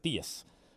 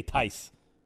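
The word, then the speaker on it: Theis: The problem is he doesn't spell it like Tice, so I always say Theus. But okay, Tice. I'm supposed to say Tice.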